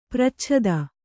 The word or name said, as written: ಪರಿಚ್ಛೇದ